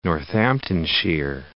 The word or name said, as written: Northamptonshire